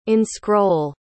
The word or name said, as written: inscroll